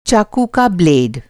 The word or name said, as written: ब्लेड